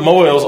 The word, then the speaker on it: Mohel: The Moel, it's pronounced like Boyle.